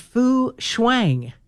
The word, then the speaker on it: Fu Shuang: The pair is here, a six-year-old male named Ping Ping and a five-year-old female named Fu Shuang. I think that's how you say it. – Yeah, you did well.